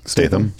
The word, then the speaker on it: Statham: How do you say his name? – Statham. – Statham? – There's no R.